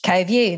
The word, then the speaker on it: CoView: I think I've said it right, CoView, is that correct? – CoView, that's right, as in both seeing something together, co-viewing.